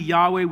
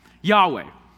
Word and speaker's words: Yahweh: But it's pronounced most likely Yahweh. We can't be exactly certain how they pronounced it 1,000 years ago, but pretty close to something like Yahweh.